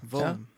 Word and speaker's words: Vaughan: Hva, hvordan uttaler man det navnet? – Steve Vaughan. Ray Vaughan. Vaughan.